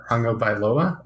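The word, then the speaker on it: Tagovailoa: It's interesting though, because you did mention, both of you mentioned that you would invest in Tua, and I'm going to try to pronounce the last name because I think it's important that we do it. Hanga Bailoa, I believe. – Yes, we'll give it to you. Yeah, that sounds great.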